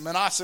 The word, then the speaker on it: minas: But he got called 10 of his slaves, and these were essentially his, his staff and they gave them ten, 10 minas. I think it's minas. How do you pronounce it? Anybody know? Manas. Manas it is.